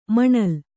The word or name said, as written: ಮೂನೆಲ